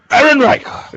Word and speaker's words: Ehrenreich: Ehrenreich? – Ehrenreich!